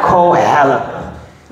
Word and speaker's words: Qoheleth: Not Cruella, but Koheleth. Okay. It's spelled Q-O-H-E-L-E-T-H, but it's pronounced Ko, Hel, with the emphasis on Hel, L, Koheleth.